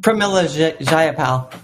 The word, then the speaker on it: Pramila Jayapal: Pramila Jayapal.